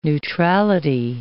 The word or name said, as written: neutrality